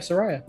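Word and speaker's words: Saraya: Soraya, how do you say Soraya? Is it Soraya? – Yeah, Soraya.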